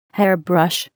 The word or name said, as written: hairbrush